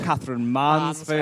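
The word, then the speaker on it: Katherine Mansfield: Catherine Mansfield.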